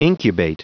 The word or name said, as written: incubate